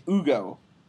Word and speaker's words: Hugo: Actually, Adam, it's Ugo. – I mean, if we're okay, it's Ugo. Yes, if you are speaking French and not pronouncing the letter H, then it is Ugo.